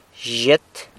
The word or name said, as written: zhyet